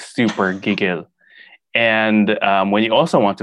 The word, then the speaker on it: gigil: Ooh, G-I-G-I-L. Mm-hmm. I'm going to put emphasis on the second part again. I'm going to say gigil. – Close. It's Gigil.